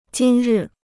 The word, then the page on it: 今日: jīn rì